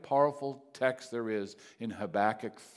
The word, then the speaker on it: Habakkuk: The book of Habakkuk, right? That's how I pronounce it. I've heard various pronunciations. Habakkuk, three chapters long.